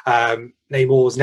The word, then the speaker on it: Namor: Is it Namor? – Yeah, Namor. – Yeah.